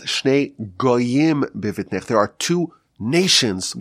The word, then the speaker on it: goyim: So the word is pronounced as goyim, nations, but the word is spelled as geim, proud ones.